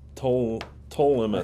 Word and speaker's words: Ptolemais: Ptolemais. – I think this P is silent. I think you got it.